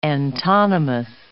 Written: antonymous

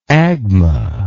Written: agma

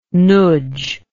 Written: noodge

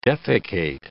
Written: defecate

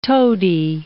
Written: toady

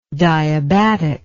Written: diabatic

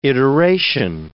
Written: iteration

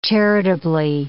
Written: charitably

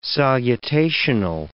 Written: salutational

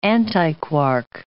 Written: antiquark

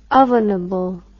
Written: ovenable

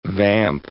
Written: vamp

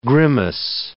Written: grimace

